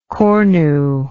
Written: cornual

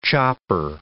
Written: chopper

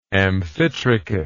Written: amphitrichate

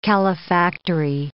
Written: calefactory

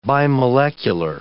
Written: bimolecular

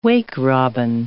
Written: wake-robin

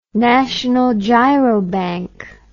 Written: National Girobank

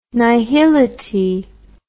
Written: nihility

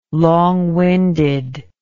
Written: long winded

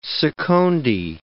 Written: secondi

